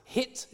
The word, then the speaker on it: hit: It looks like it, and just like it in modern day English, it in Old English, it's pronounced hit. It's got a h on the front, and it doesn't really inflect for case.